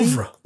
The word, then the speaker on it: oeuvre: Harold Abramson, an LSD pioneer, entered the MK Ultra how do you say that word? O-E-U-V-R-E? Ouvre. It's a French word. Ouvre.